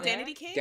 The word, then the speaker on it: Danity Kane: I didn't know the Daddy Kane Showstopper. Y'all don't know that either. – Oh, Danity Kane? – Danity Kane.